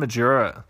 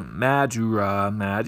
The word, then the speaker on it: Majura: It's pronounced Majura. And I go, well, you know, since you're the source, I have to believe you.